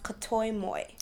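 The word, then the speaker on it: kataomoi: What is Katamoy? – Katamoy. It's actually pronounced Katoimoy. – Katoimoy. – And in Japanese, that means unrequited love.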